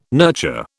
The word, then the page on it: nurture: British English